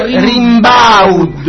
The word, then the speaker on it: Rimbaud: Allora si pronuncia Rimbaud. Arthur Rimbaud.